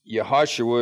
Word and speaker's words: Yehoshua: Yehoshua is how you say Joshua in Hebrew, a variant of the words Yeshua.